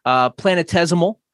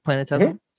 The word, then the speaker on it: planetesimal: Planetesimal. I believe I said that right. Planetesimal.